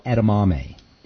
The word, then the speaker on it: edamame: edamame. Edamame is how you say it.